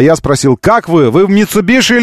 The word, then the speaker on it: Mitsubishi: Skoda Kodiaq и Mitsubishi, говорится Mitsubishi, а не Mitsubishi. Это уже каноническое произношение, которое было опубликовано руководством компании, японским руководством компании Mitsubishi в России.